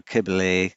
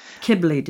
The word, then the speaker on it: Kybele: And there was, I'm going to pronounce this wrong now, but the Roman goddess, is it Kylebe? The one who, her priests castrated themselves, and it has been suggested that they were transgender priests. – Yeah, this is the great mother or the mother goddess or Kybele. She sort of originates in the East. – Kybele, did you say? I don't want to mispronounce the name of a goddess who has people castrate themselves. So Kybele, right, okay.